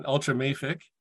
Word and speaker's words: ultramafic: The first thing I'll do is correct your pronunciation, ultramafic.